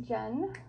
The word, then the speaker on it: Jenn: Well, I'm Jen.